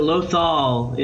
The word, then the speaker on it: Lothal: He's going to tell us all about, is it pronounced Lothal? – It's pronounced both in the show. I think Lothal is what you hear the most though.